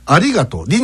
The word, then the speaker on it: ありがとう: ありがとうという言葉がありますが、標準語ではありがとう、りに来ますね、アクセントがね、ありがとう、これが大阪弁だとありがとう、極端にやってますよね、ありがとう。